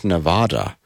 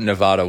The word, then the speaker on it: Nevada: And October 27th through November 1st, Las Vegas, Nevada, as they pronounce it. – It is. It's Nevada.